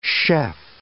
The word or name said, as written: chef